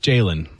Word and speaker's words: Jalon: J-A-L-O-N. – I know, I think it's Jalen. – It is.